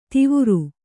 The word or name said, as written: ತವರು